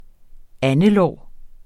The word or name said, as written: andelår